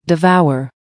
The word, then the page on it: devour: American English